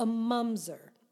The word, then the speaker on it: mamzer: It's spelled M-A-M-Z-E-R, but it's a mumzer.